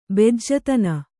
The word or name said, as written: ಬೆಜ್ಜತನ